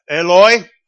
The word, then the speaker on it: Eloi: The Aramaic pronunciation of my God is almost identical to the Hebrew pronunciation of Elijah. Eloi, or Eli.